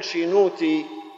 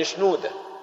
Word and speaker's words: شنودة: ربما تجدون في المتحف القبطي في مجموعة مورغان بعض من عظات الأنبا شنودة باللغة القبطية، موجودة باللغة القبطية واللغة القبطية الصعيدية، لأن اسم شنودة النطق بتاعه صعيدي، بالبحيري بنقول شينوتي لكن بالصعيد شنودة، التاء بتتنطق دال،